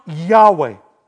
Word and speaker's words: Yahweh: Remember, Hebrew doesn't have any vowels in it, but it's pronounced Yahweh.